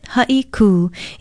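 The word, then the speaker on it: Haiku (Haʻikū): It's spelled H-A-Okina-I-K-U with a kahako or makron over the U. Pronounce it Haiku. It means a sharp break or to speak abruptly and is often confused with the Hawaiian term haiku for a type of poetry. Remember, it's Haiku.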